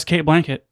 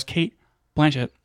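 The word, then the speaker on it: Cate Blanchett: Anyways, it stars Kate Blanchett. Jesus, I said it wrong again. It stars Kate Blanchett.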